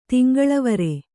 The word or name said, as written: ತಿಂಗಳವರೆ